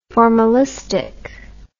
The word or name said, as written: formalistically